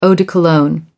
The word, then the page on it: eau de cologne: American English